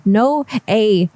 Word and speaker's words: Noe: His name is N-O-E, pronounced no A, like, hey, what's up? – Hey, buddy.